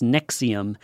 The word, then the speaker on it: NXIVM: It's spelled N-X-I-V-M, like the Roman numerals, but it's pronounced Nexium.